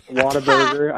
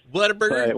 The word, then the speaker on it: Whataburger: My parents are from Texas, so going to Taco Bueno and Whataburger. I'm sure I pronounced that wrong because they told me all week I pronounced it wrong. – Whataburger.